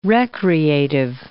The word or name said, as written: recreative